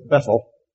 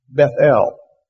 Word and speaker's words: Bethel: And what I was reading said, when you pronounce it, it's not, we would normally say it's Bethel. It's Bethel. Both syllables should be highly accented.